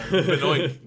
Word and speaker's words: Benoit: Calls Chris Benoit Chris Benoit. – I think that's quite possibly my favourite mispronunciation by Jericho. – Benoit.